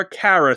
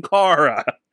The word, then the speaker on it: Kara: They call her Kara throughout the whole movie. Her name is fucking Kara. Drove me nuts the entire movie. Her name is Kara. It's a short A, not a long A.